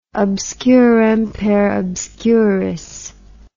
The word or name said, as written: obscurum per obscurius